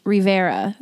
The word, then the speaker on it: Rivera: So it's EMMALEIGH Rivera. It's just River with an A at the end.